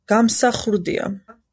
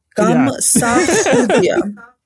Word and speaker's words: Gamsakhurdia: Gamsakhurdia. – Khurdia, yeah. – Gamsakhurdia. – Gamsakhurdia.